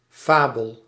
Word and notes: conte: [kɔ̃t]; France

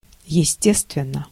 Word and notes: естественно: /jɪsʲˈtʲestvʲɪnːə/

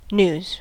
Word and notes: news: /n(j)uːz/; [njuːz]

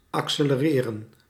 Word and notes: accelereren: [ɑksɛləˈrɪːrən]

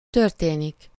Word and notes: történik: /tør.teː.nik/

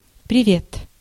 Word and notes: привет: [prʲɪ.ˈvʲet]

